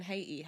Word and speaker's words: Haiti: In Haiti.